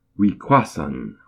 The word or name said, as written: Wiqáhsun!